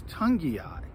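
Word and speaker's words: Togiai: I don't know if the Eagles agree with me, but it's gotten to the point where I learned how to say his name, Ed. He's been around for a while. It's Tungiai. Noah Tungiai.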